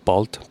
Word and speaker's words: Bold: Well, like the Mongolian pronunciation is like Bolt, but like it's spelled B-O-L-D.